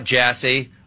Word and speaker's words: Jassy: Now, I know nothing about Jassy other than his last name rhymes with Sassy.